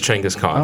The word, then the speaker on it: Chinggis Khan: If you say his name correctly. – Really? – Yeah. – Oh, so yeah, I thought you said it wrong, but that's how you say it? – Yeah, it's Chinggis Khan.